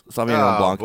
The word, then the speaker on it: Sauvignon Blanc: I'm drinking a Napa Valley Sauvignon Blanc. Is that how you say it? – Yes. Sauvignon Blanc.